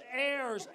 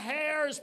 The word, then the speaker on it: heirs: I wanted to say silent H. It's heirs. Heirs, not hairs, but no, he was on the roll.